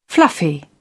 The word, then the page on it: fluffy: British English